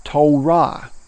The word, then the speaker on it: Torah: it gives us the Hebrew word Torah, T O R A H, pronounced To and then Ra.